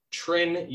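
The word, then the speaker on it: Trine: So we'll move into the second game against our mispronunciation last week is Trin University. – It is Trin.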